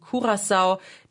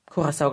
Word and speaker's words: Curaçao: Beides kann man sagen. Curaçao ist die niederländische Aussprache und Curaçao, die Papiamentu-Aussprache, das ist die karibische Kreolsprache, die auf Curaçao gesprochen wird.